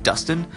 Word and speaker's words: Dustin: Or if you'd like to reach me at the Bike Shop Boyfriend page on Facebook, it's under my name, Dustin, D-U-S-T-I-N, white as in the color. Again, that's Dustin, D-U-S-T-I-N.